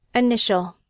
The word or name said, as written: initial